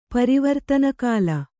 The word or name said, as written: ಪರಿವರ್ತನಕಾಲ